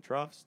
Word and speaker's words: troughs: I'll never forget because you're just talking about generational crests and troughs. Did I say that right? Troughs? – Yes. – Okay. – Yeah, you got it.